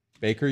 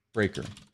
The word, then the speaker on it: Braiker: And it's spelled B-R-A-I-K-E-R. – D-R. How do you spell A-I. – It? – A-I. B-R-A-I-K-E-R. – Baker, you said? – Breaker. – Breaker.